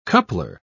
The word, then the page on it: coupler: British English